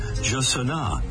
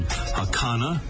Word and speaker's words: jacana: The Brazilian Portuguese way is Jasana. Spanish versions include Hakana and Hasana and Asana. There may be others, but I think we'll quit there.